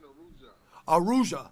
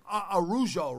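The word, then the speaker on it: Araújo: I think it's pronounced Vivian Arruja. – Thank you. Thank you. See what I mean? He's a good man. Viviana Arrujo, right? – Yep.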